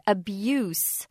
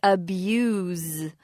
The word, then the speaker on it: abuse: If it's a noun, it's an S sound, abuse. But if it's a verb, it's a Z sound, abuse.